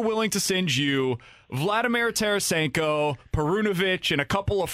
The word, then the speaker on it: Tarasenko: It becomes somewhat intriguing because of the injury scenario of Tarasenko, and that you said that right.